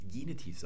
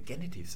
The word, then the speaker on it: Genitiv: Mir hat jemand eine E-Mail geschrieben und mich gefragt, warum ich immer, wenn ich Genitiv sage, Genitiv sage, mit einem kurzen e vorne. Eigentlich ist doch das e, die Silbe offen, deswegen muss im Deutschen das e lang gesprochen werden.